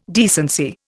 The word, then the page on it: decency: Indian